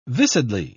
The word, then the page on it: viscidly: British English